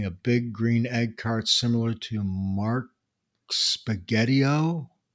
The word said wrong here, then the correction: Spagnolo: How do you pronounce that? Spagnolo. Mark Spagnolo.